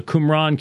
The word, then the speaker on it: Qumran: the Qumran community, which is how you pronounce it.